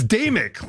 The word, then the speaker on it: Damick: It's Damick, like night and day, Mick.